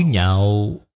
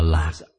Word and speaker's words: nhạo: Chữ nhạo có thể hiểu là sở thích. Nó có một cách đọc gọi là lạc. Lạc là sở thích. Cũng có thể đọc là nhạo.